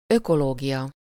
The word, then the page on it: écologie: [l‿e.kɔ.lɔ.ʒi]; France